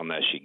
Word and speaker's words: Nesci: This is Deputy Chief Sal Neshi, Guilford Police. – Hi. So it's Neshi, like S-H-I. I want to pronounce it right. Hi. – N-E-S-C-I.